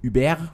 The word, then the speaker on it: Hubert: Wobei ich glaube, Uber wäre dann wahrscheinlich die französische Aussprache für Hubert, ne? – Über, ja. Aber dann musst du dieses T noch mitdenken, aber nicht aussprechen. – Und das H genauso.